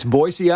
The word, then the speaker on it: Boise: People always say it with a Z, right? – That's right. It's Boise, Idaho.